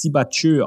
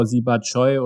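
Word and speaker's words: Siebatcheu: Äh, dieser neue Stürmer, ich weiß ja, der, der wird jetzt Sibachö ausgesprochen. Jordan ne? Sibachö. Ne? – Ja, ja, ich hatte in der Saisonvorschau Siba Choi oder so, weil ich nicht wusste, wie er aus. Also er heißt Siba Chö.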